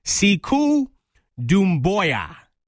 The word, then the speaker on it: Sekou Doumbouya: Okay, number 9, I'm going, and I'm probably going to butcher this name right now, Sekou Doumbouya from... – That's actually how you pronounce it.